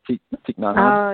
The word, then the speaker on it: Thich Nhat Hanh: Thich Nhat Hanh? – Oh, yes.